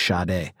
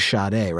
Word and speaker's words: Sade: I think it's like Sade, but you do it with like you're like not paying attention to the fact that you're pronouncing a sh at the beginning. It's like Sade. It's a quick sh.